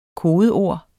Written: kodeord

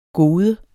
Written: gode

